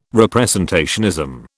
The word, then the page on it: representationism: British English